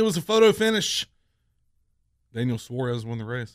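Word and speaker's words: Suárez: Nobody can say his name right. It's Daniel Suarez, not Suarez.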